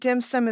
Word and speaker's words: dim sum: And I understand that dim sum is a Cantonese pronunciation.